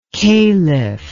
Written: kalif

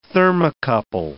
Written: thermocouple

